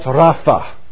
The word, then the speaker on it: Rapha: In the Hebrew, it is pronounced Rapha.